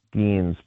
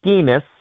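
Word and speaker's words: Skenes: I can't watch Paul Skeens because every time I watch him, I hear Tausch mispronouncing his name as Paul Skeenis, which rhymes with another word.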